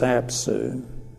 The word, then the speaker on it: Apsu: E-L-I-S-H. The account describes two primeval waters. The male primeval water is Apsu, A-P-S-U, Apsu, and the female is Tiamat, the salt water. Tiamat, T-I-A-M-A-T.